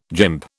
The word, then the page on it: gymp: British English